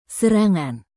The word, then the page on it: serangan: Indonesian